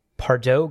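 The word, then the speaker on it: Pardot: One is Pardo Kynes. I believe that's the correct pronunciation. Silent T.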